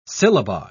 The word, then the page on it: syllabi: British English